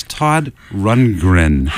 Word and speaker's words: Todd Rundgren: Well, you got his name right. It's Todd Rundgren.